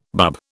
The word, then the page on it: bub: British English